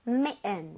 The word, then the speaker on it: mitten: So 얘 같은 경우도 S를 써주시고 I의 강세를 많이 줘서 사실 들을 때는 메은, 은처럼 들을 확률이 높아요. 그러니까 미튼 해주면 좋을 텐데 메은 이렇게 많이 합니다. 이거 발음 한번 해볼게요. 메은, 메은. – Mitten. – Ooh. – Mitten. – That's right.